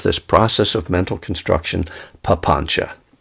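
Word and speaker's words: papañca: You know, papancha, just one of the favorite words of Pali. You should learn it. P-A-P-A-N-C-A. C is pronounced like C-H in Pali. P-A-P-A-N-C-A. Papancha.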